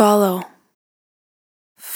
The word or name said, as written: Follow